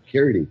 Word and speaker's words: Carradine: You know, I'm probably aging myself for some people that are listening, but it's reference back to the old show with David Carrigan, or Carradine, I think. I'm pronouncing that wrong. – That's right, David Carradine. – Carradine, that's right.